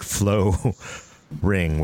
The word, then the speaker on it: Floe Riing: And of course, again, the thermal take flow ring, which is spelled F-L-O-E. R-I-I-N-G. – It's ring. Not a typo.